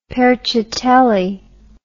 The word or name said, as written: perciatelli